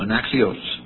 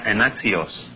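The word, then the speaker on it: anaxios (ἀνάξιος): See, it's the short O, anaxios. – La misma palabra como adjetivo tiene una O corta que se dice y se pronuncia anaxios.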